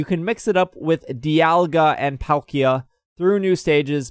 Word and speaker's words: Dialga: I think I'm finally saying it right. Because that's how the Pokedex 3DS app says it. Dialga.